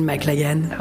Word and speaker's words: MacLachlan: Kyle MacLachlan. Kyle McLagan. Oh, merci.